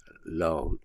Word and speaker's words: Laon: that's pronounced Laon, L-A-O-N, France.